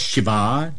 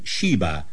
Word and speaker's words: Sheba: In the Hebrew, they are known as Shiva, Dedan, and Tarshish. In your English, you pronounce them Sheba and Dedan and Tarshish.